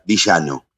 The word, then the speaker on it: Villano: Buen día, como el malo de la película, Villano.